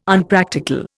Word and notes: Unpractical: Indian

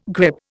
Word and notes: grip: Indian

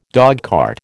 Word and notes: dogcart: American English

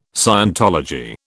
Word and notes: scientology: British English

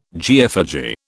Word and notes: geophagy: British English

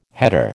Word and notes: header: American English

